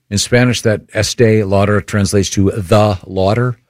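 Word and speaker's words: Estée Lauder: Is it pronounced Estee Lauder? Estee Lauder, yes.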